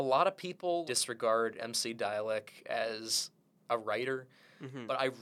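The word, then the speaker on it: Dälek: It's spelled D-A-L-E-K, so almost like Dalek, but they've got like an umlaut over the A, so it's Dialect.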